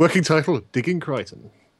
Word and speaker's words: Crichton: It's pronounced Crichton, by the way.